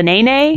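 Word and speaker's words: nēnē: The nay-nay? The nay-nay. – Nay-nay. Nay-nay. Mm-hmm. Yeah. No, oh, of course. In Hawaiian, you pronounce those E's as like an A sound.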